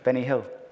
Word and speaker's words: Benny Hill: ¿Has notado cómo dije Benny Hill? – Sí. O sea, ¿has dicho? ¿Y cómo se dice en inglés? – Benny Hill.